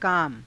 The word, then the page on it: kaama: Hindi